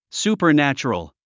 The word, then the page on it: supernatural: sùːpə r nǽtʃərəl; スーパァナチュラル